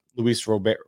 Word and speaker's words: Luis Robert: Luis Robert is how you pronounce it.